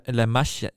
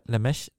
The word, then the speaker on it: La Masia: Skal vi lige starte sådan helt bredt, så øh, La, La, Masia, La, La, Masia, La Masha, hvordan udtaler man det? La, La, La Masia for helvede. La Masia.